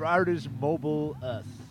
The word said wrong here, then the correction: Mobilis: Art is Mobilis.